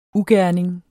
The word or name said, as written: UGERNINGERNES